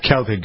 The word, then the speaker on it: Celtic: Go back in history to Celtic or Celtic, some people like to use it's Celtic with a hard C with most people.